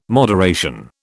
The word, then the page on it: moderation: British English